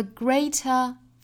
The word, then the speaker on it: greater: The greater the risk, greater 和 risk 里面都包含一个音素，就是 r 的发音。 Greater, greater. 在发这个单词的时候，不要发成了 greater, 应该是 gr, gr. 我们注意两个音素组合在一起，这个辅音连缀发的是 gray 很快过渡到后面的双元音上